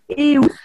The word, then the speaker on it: Eus: pas très loin d'ici, qui s'appelle Eus, ça s'écrit E-U-S, ça s'appelle Eus,